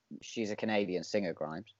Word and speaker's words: Grimes: Is it Grimes or Grimms, or how do you pronounce that name? Again, I'm not very good with names. – Oh, the artist. – Yeah, Grimes. – Grimes.